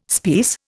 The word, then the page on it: speiss: Indian